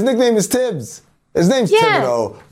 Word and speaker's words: Thibodeau: He says it's Thibodeau. That's how he pronounces his own name.